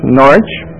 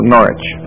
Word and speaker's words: Norwich: A guy that owns a shoe store in Norwich. I think that's the way you pronounce it, Norwich. You leave out the W.